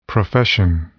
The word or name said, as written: profession